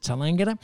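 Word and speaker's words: Tallangatta: That's how you pronounce it, Talangata.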